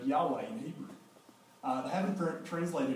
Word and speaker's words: Yahweh: And so all the Hebrew nerds today, the Hebrew scholars, will tell us it's pronounced Yahweh, is how you pronounce it.